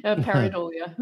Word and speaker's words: pareidolia: So how do I say it? – J'aime ça beaucoup. Pareidolia.